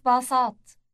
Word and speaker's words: باصات: موقف باصات. موقف باصات. – Note the ending at as in pat.